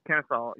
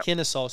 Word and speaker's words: Kennesaw: Kennesaw, yeah. – Kennesaw.